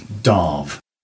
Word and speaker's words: Damh: But your name is spelt D-A-M-H, isn't it? – Yeah. – So how do you pronounce it, really? – Okay, officially, it's Darv. – Darv. – D-A-R-V, like that.